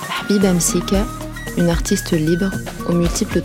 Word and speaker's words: Msika: Habiba Msika, ou plutôt Habiba Msika, prononcée en arabe, est une chanteuse, comédienne et indépendantiste tunisienne.